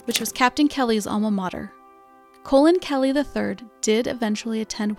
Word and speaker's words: Colin: The late Colin Powell adopted the pronunciation of his name as Colin after hearing how Colin Kelly's name was pronounced.